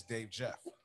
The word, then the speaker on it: Dave Jeff: Hey, my name is Dave Jeff.